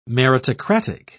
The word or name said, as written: meritocratic